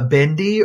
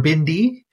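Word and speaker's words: bindi (भिंडी): Yeah, so for those non-Hindi speakers out there, yeah, bendy or bindi is the pronunciation. That's the what we say is Hindi word for okra.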